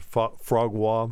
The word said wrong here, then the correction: foie gras: Yeah, foie gras.